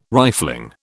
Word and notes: rifling: British English

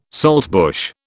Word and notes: saltbush: British English

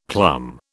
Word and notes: plum: British English

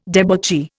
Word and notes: debauchee: Indian

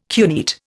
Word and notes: cuneate: Indian

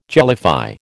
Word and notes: jellify: American English

